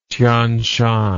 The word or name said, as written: tian shan